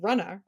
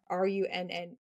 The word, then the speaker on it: Runnah: you can check out my podcast called Runna, RUNNAH, because that's how we pronounce it in Massachusetts, Runna.